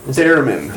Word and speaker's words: theremin: How do we pronounce that? Theremin? – Theremin.